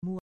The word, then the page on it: mu: grec